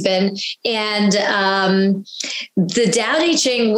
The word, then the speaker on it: Tao Te Ching: So I say Tao Te Ching. Some people like the T sound say Tao Te Ching, but I think the official pronunciation is Tao Te Ching.